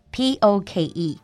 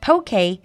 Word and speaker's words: poke: Now, another rising star in the brunch world is something called poke, P O K E. And it's not pronounced poke because poke is from the native language in Hawaii.